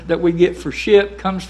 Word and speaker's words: schip: Our current spelling, though, comes from the Dutch word for the same thing, which is spelt S-C-H-I-P. And if you pronounce that, you would come up with ship.